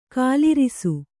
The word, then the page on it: ಗೋಲರಸ: kannada